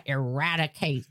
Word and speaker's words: eradicate: And then you're like, I'm going to eradicate this. – That's good. Emphasis on the rat. – Thank you. – Because it sounded like you said erad.